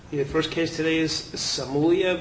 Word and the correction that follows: Somalia: Am I pronouncing that name right? – We think we have agreement, Somalia.